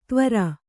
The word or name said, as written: ತ್ವರ